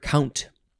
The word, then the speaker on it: compt: And there's more at the word count. Oh, interesting. I think I just made a connection. We had that weird word in yesterday's episode, C-O-M-P-T, which could be pronounced count.